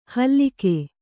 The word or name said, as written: ಹಲ್ಲಿಕೆ